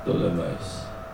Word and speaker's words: Ptolemais: Ptolemais.